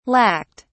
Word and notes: lacked: British English

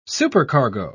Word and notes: supercargo: British English

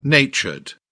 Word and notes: natured: British English

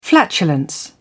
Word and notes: flatulence: British English

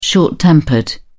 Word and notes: short tempered: British English

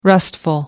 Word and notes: restful: American English

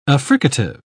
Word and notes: affricative: British English

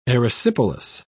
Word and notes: erysipelas: British English

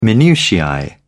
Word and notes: minutiae: British English

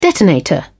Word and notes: detonator: British English

